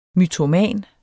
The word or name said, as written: mytoman